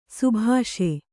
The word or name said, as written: ಶಾಭಾಸ್